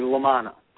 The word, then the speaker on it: Lamanna: Half of it. You're 50% correct. It is Nick, and the last name is actually Lamanna.